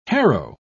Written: harrow

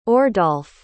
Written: ordalf

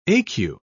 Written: ecu

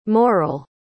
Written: morell

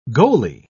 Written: goalie

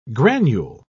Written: granule

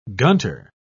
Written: gunter

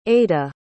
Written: ada

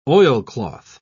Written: oilcloth

